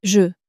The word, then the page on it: je: French